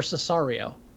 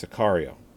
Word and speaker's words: Sicario: Now, is Sicario or Cesario? – It's Sicario. – Sicario. I've been pronouncing it wrong.